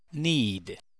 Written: need

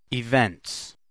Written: events

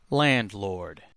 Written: landlord

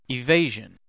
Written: evasion

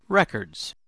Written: records

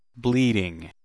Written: bleeding

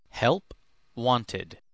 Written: help wanted

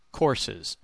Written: courses

